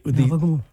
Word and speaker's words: capicola: What Americanized? How do you say it? Capicola, right? Capicola. How do you say it? – Gabagool. – Gabagool. You hear that? – Yeah, but it's like cannoli. – It's a different way of saying it.